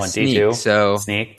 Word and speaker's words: Snyk: So Veeam Software through 2019, then a group called Snyk, I think is how you say that, through 2022. – Yeah, Snyk.